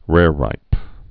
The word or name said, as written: rareripes